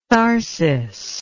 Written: tharsis

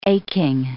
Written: aching

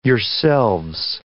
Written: yourselves

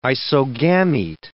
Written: isogamete

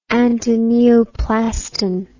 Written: antineoplaston

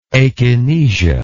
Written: akinesia

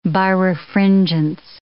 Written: birefringence

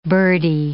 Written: birdie

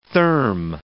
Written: therm

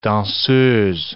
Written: danseuse

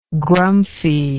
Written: grumphie

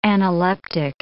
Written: analeptic

